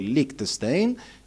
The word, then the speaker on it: Lichtenstein: Le serigrafie di prodotti di consumo e divi del cinema di Andy Warhol o i fumetti ingigantiti di Roy Lichtenstein, di cui parleremo più tardi.